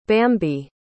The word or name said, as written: bambi